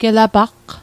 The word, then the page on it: gelapaqq: Mi'gmaq